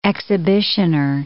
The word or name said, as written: exhibitioner